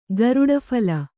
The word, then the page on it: ಗರುಡಫಲ: garuḍa phala